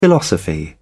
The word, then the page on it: philosophy: British English